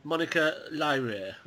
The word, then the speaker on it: Monica Lyrae: And the composer, Monica Lyrae.